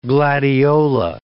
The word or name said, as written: gladiola